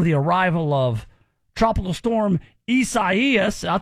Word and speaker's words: Isaias: Come on, Isaias. That's how you say it.